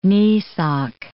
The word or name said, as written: kneesock